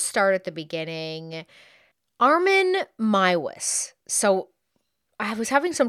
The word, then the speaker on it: Meiwes: His last name is spelled M-E-I-W-E-S. And in the documentaries I watched, they were all pronouncing it Maiwis.